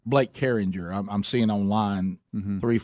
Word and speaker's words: Carringer: Yeah, the Carringtons. – Name? Oh, didn't you know, you might be right. – The name is spelled C-A-R-R-I-N-G-E-R. – Carrington. – No, he knows Jer. – Carringer. – Oh, Carringer.